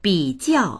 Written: bi3jiao4

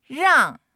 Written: rang4

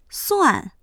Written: suan4

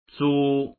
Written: zu1

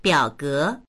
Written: biao3ge2

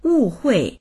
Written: wu4hui4

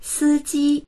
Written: si1ji1

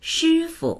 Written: shi1fu

